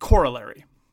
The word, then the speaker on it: corollary: And Americans pronounce it corollary. And that's the American pronunciation. That's the emphasis on the first syllable as opposed to the emphasis of the second syllable, which is the more traditional British pronunciation.